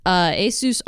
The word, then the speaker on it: Asus: Asus, as I learned that it's pronounced, Asus